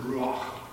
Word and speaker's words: ruach: The word in Hebrew is ruach. It's got a ha on the end of it.